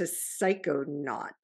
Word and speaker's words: psychonaut: So you are, I love in your bio, you say entrepreneur to psychonaut. Psychonaut? Did I say that correctly? – You said it correctly.